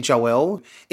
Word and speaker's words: Joelle: Funniest thing is that I say Joel all the time to people. – So I've heard you say that. – Exactly. You were like, why are you lying? That's not what you say your name is. So I say Joel because where I grew up, that's how everyone kind of talks and it's just quicker. But it's actually Joelle. It's actually really spelled J-O-E-L, but people would say Joel as well because I say it like that.